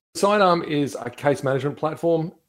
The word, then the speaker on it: Cydarm: First up, Vaughn Shanks is the chief executive and co-founder of Sidearm, and that's spelled C-Y-D-A-R-M.